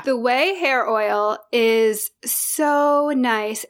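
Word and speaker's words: OUAI: The first one is it's pronounced Way, but it's O-U-A-I. It's that hair care brand Way.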